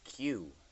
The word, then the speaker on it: queue: Q. As in you Q into something, I guess? – Yeah. – And there's... – Yeah. – It's spelled Q-U-E-U-E. If you take out the U-E-U-E, yeah. – Just the letter Q.